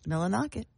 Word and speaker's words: Millinocket: Did I pronounce that correctly? – You did. – Come on, give me a treat. – Millinocket.